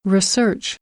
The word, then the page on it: research: noun